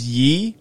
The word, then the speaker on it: Ye: It's Ye? – See, yeah. I think it's pronounced Ye, because it's just the continuation.